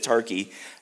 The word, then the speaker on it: Tarke: It's spelled T-A-R-K-E. That's what it is, Tarkey.